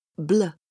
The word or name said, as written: bl